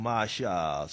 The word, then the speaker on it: Asa: It is a song taken from the album, self-entitled album Asha, spelled A-S-A, pronounced A-S-H-A.